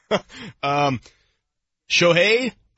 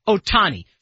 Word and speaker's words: Shohei Ohtani: How do you say his name? – Shohei Ohtani. – Ohtani. – I'm sure I butchered it. – No, that's good. Ohtani.